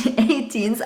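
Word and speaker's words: A*Teens: Weil das hieß nämlich 18s ausgesprochen tatsächlich.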